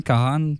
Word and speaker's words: Kahan: Ça se prononce bien Kahan parce qu'il l'a dit dans le show, son propre nom.